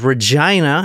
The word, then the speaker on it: Regina: The next stop was Regina, and that is how you say it, not Regina. It's Regina.